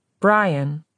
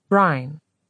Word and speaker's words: Brian: Mr. Gleason had a brogue that was different from his mother's, but they both said Brian, like Brian, blending the syllables into one.